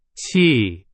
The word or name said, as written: 起